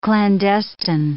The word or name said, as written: clandestine